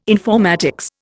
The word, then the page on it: informatics: Indian